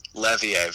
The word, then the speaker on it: Levier: Levier, very French.